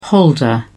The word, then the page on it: holder: British English